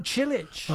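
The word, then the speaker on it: Čilić: M-A-R-I-N. And his surname is spelled C-I-L-I-C. – Oh, Cilic.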